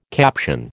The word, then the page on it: caption: American English